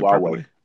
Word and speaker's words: Huawei: So how do you pronounce it? Huawei. Huawei. Okay.